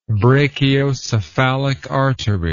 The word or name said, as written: brachiocephalic artery